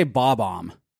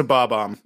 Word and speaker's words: Bob-omb: How do you say that particular character? – I would say Bob-omb. – It is a Bob-omb.